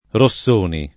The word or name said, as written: Rossoni